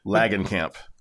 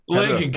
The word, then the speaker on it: Langenkamp: Langenkamp. – Langenkamp.